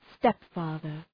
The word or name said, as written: stepfather